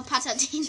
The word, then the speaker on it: Patatina: Ich kann es nicht aussprechen, Pet. Patatina. Genau, Patatina.